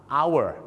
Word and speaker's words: hour: ชั่วโมงต้องออกเสียงว่า hour นะครับ hour ค